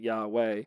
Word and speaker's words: Yahweh: I don't maybe I missed that part where they explained why they say Yahweh, but it looks a lot like Yeehaw on the screen, but they pronounce it Yahweh.